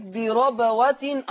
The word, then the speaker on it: بربوة: أيضا لفظ بربوة أصابها، حفص يفتح الراء على فكرة، يقول بربوة، وأيضا عندنا النقل، من هنا الساكن؟